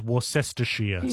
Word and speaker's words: Worcestershire: Let me give you some help, AJ. It's Worcestershire sauce. Worcestershire.